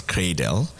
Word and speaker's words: Craydel: And I'd like us to, you know, spell the word cradle, pronounced as cradle, spelled C-R-A-Y-D-E-L, cradle.